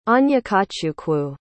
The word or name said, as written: onyekachukwu